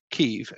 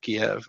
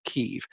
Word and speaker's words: Kyiv: And two things I've learned in this process is it's now Kyiv instead of Kiev. I guess that was a Russian pronunciation of the word Kiev, was a Russian pronunciation. So now we call it Kyiv.